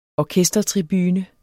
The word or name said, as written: orkestertribune